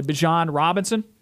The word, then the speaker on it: Bijan Robinson: Sophomore running back, I hope I'm saying this correctly, Bijan Robinson. – I believe that's correct.